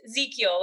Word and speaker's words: Ezekiel: It's Ezekiel, like Ezekiel without the E. – Okay, like the Ezekiel bread without the E. Got it.